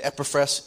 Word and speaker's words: Epaphras: And Epaphras is the pronunciation of his name today.